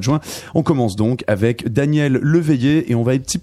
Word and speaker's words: Daniel Leveillé: Tout ça pour illustrer ce travail du Québécois Daniel Leveillé. Est-ce que je le prononce bien, Amélie Blaustein Oui, c'est Daniel Leveillé.